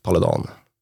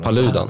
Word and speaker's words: Paludan: Och den här personen som jag inte riktigt kan uttala namnet på, Rasmus Paludan, Paludan Paludan? Paludan. Paludan. – Paludan. Ja. Ja.